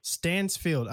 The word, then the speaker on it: Stansfield: Stansfield? – Stansfield. – Stansfield.